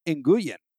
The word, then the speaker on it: Nguyen: Nguyen is probably a better way to say it. All Vietnamese words are one syllable. So Thompson Nguyen is like pretty good already.